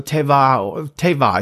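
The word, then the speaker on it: tevah (תֵּבָה): Noah's ark is actually the word tebah, and it is translated as a chest, an ark, or a coffer. So tevah is probably a better Hebrew pronunciation with the B sort of having a V sound.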